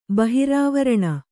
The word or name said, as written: ಬಹಿರಾವರಣ